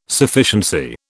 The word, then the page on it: Sufficiency: British English